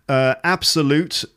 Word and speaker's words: absolute: One of them is absolute. I think it was absolute pleasure. So the stress on the word absolute is on the first syllable, so an absolute pleasure.